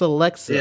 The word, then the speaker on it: Alexa: Is it Alexa or Alexia? – I think it's Alexa. – It's Alexa.